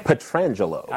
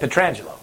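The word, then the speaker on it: Pietrangelo: Petrangelo. – I don't want to disrespect the dude. I can say that. Petrangelo.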